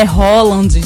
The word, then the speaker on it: Haaland: O pessoal tava torcendo pra Noruega, porque disse que Haaland, o nome dele, a pronúncia, né? Ou é Holland? É Holland, gente. É uma coisa norueguês, assim.